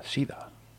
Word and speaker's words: Cedar: que realmente Cedar se pronuncia Sida, suena un poco feo.